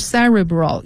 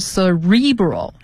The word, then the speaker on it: cerebral: 这个词呢， cerebral 它是代表大脑的，它有两个读音，一个是 cerebral，一个是 cerebral，也就是它的重音可以在第一个音节，也可以在第二个音节。